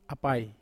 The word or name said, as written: apai